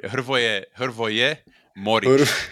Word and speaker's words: Hrvoje Moric: You started well, but you ended it. No, it's all right. Hrvoje Moric. Kind of like Luka, the football player, you know, Luka Modric without the D, Moric.